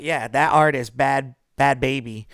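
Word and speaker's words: Bhad Bhabie: We're agreeing that's how it's said, right? – Yeah, it's Bad Baby. – Okay, Bad Baby. I don't know why she has to spell it like that, but she does.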